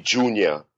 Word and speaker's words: Joonya: So because of my background, which we'll get into, it's actually Joonya.